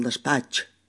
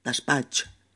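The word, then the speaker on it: despatx: O el despacho, que decimos all despatch, despatch.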